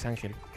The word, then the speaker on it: Ángel: Es que no era Angel, es Ángel. Porque era como latina. – Ah, era Ángel. O sea, se pronuncia así Ángel.